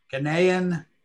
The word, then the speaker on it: Ghanaian: Who's a Ghanaian, is that how it's pronounced? – Ghanaian.